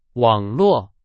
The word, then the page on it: 网络: Chinese